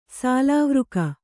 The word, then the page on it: ಸಾಲಾವೃಕ: kannada